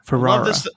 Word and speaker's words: Ferrara: Mr. Abel Ferrara or Ferrara. – Ferrara.